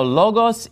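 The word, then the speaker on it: logos (λόγος): And notice in logos, I'm putting the stress on the first syllable, because that's where the accent mark is. En arhi in o logos, ke o logos in pros ton theon.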